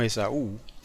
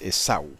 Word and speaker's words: Esaú: Por eso es que no decimos Esaú, porque en hebreo ese nombre no se acentúa en la U, sino en la A, es Esaú.